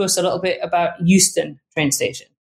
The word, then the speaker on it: Euston: The first train station that we're going to start with is Euston, which I am always mispronouncing and saying Houston, but it isn't. It's E-U-S-T-O-N, so Euston.